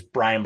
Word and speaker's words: Bryan: And my name's Brian Barletta,